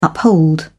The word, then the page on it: uphold: British English